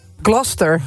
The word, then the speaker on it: Gloucester: Gloucester. De Engelse plaatsnaam Gloucester spreek je uit als... – Gloucester. – Gloucester. – Ja. – Als één vloeiend woord. Gloucester.